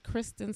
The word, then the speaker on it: Kristen: And um, my personal page is Kristen Sivills K R I S T E N S I V I L L S.